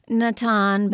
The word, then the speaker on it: Natan: Well, the main character, or the character who is a seer, and do you pronounce it Natan? – I've used the names from the Hebrew scriptures, so Natan, but Natan. In the King James Bible, he's Nathan.